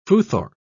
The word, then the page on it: futhorc: British English